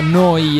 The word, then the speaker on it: Neu: Neuradio.it, si scrive Neu, si pronuncia Noi e vuol dire nuova, nuova emittente urbana live 24/7 da Bologna.